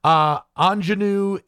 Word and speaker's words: Anjanue: and I really hope I'm pronouncing this correctly, forgive me if I'm not, Anjanue Ellis for King Richard. Actually, I did pretty well with that first name.